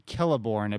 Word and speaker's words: Celeborn: So daughter of Celeborn or Celeborn, I believe. I think the C's are pronounced with a K there. – Oh. – Right. – So everyone, like Celtic.